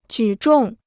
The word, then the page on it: 举重: jǔ zhòng